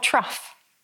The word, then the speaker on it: trough: It's a dialect version of trough. Trough, not trough. A trough, because trough is one of those many confusing words in English that is spelled O-U-G-H, even though it doesn't sound anything like it.